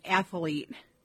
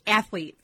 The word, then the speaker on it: athlete: Another sports word that a lot of people do is athlete. It's athlete.